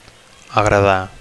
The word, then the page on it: agradar: Catalan